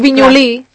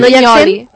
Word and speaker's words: Vinyoli: Doncs per això no li diguis Vinyolí. Li has de dir Vinyoli. – Es diu Vinyolí. – No, Vinyoli. No, tu li estàs posant l'accent a la I.